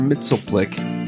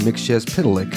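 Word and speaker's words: Mxyzptlk: Peter Godot has been cast as the DC Comics character that most people call Mr. Mitzelplick. But if you pronounce it correctly, it's Mr. Mixchez Pitalik.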